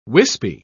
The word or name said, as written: wispy